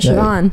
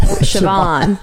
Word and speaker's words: Siobhan: Siobhan. – Siobhan with a B. – No, with a V. Siobhan. – It's spelled with a B. – That's why it throws people off.